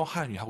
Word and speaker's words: 汉语: 教汉语, 지금 저희 둘이 저 한위 하고 있는 거잖아요. – 没错。 – 네, 그렇습니다. 한위. 중국어 발음하실 때 한위 아니고 와유 발음은 입술 동그랗게 말아서 한위.